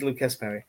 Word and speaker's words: Lucas: Is it Luca? It's still Lucas, isn't it? They do pronounce the S. It's not Luca Perry. – Yes. – Yes, I think it's Lucas Perry.